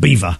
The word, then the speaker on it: Belvoir: It's spelt Belvoir, but it's pronounced Beaver.